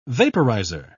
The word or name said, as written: vaporizer